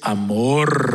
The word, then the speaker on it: amor: Step up and share his amor. Roll that R. Come on, look to somebody next to you. Step up and share his amor. Okay, if you can't roll your R, you can say amor.